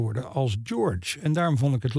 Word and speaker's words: George: Dus dat eh, bevestigde mijn vermoeden dat die naam inderdaad eh, zo moet worden uitgesproken. Katie George dus.